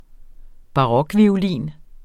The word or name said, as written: barokviolin